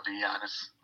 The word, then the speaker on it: Giannis: The soft G pronunciation of Giannis, almost with a Y sound.